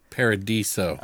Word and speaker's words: Paradiso: She was in Guest House Paradiso. Did I say that right? – Paradiso. – Oh, Paradiso, whatever.